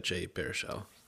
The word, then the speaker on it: Baruchel: I do like Jay Baruchel. Is that how we pronounce his name? Yeah. Baruchel? – Jay Baruchel.